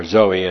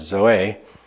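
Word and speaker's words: zoe (ζωή): It's the Greek word zoe, and or zoe, as some people say it. The Greeks, of course, put the emphasis on the E, so it's zoe.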